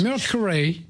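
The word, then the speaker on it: Mercurey: Mercuray.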